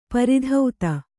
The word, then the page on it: ಪೂರ್ತತೆ: pūrtate